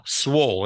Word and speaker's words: swole: Like swollen, but without the N. He's swollen. No, swole.